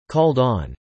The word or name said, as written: called on